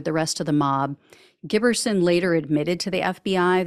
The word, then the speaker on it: Giberson: So 22-year-old New Jersey resident Larry Giberson, I think is how you say his last name, G-I-B-E-R-S-O-N. He traveled to D.C.